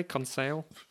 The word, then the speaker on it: Conseil: Conseil. – That's how I'd say it. – Yeah. Okay, so this this comes at a point where they're, I think they're talking about their possible freedom. Mm-hmm. Aranax, Conseil, and Ned Land end up de facto prisoners on board yes.